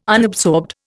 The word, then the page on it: unabsorbed: Indian